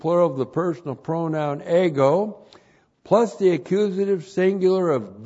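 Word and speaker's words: ego (ἐγώ): E-G, long O, ego.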